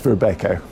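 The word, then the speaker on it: Robeco: Depends if you're Dutch. It's Rebecca.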